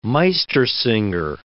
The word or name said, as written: Meistersinger